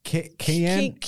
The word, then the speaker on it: Keanu: Hello, Keanu. Canoe.